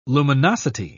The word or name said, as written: luminosity